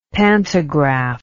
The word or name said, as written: pantagraph